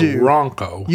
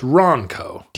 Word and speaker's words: Ronco: I think you have to hit the N a little bit harder. It's not Ronco. – You do. You don't want it to rhyme with Bronco. – No, it's Ronco.